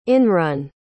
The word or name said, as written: inrun